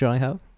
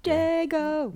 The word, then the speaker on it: Jago: Like Jago? – Yes, please. – Okay. – Jago!